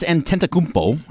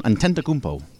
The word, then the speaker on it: Antetokounmpo: All right, so Giannis Antetokounmpo, I think I said that right, John. Antetokounmpo.